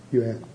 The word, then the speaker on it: Yuan: And they said, you must pay us 400 Nisigi, Y-U-A-N. How do you pronounce that? Yuan.